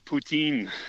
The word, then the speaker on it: poutine: Nah, poutine. Now, which is not spelled like the like Vladimir. It's Poutine.